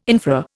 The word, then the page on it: infra: Indian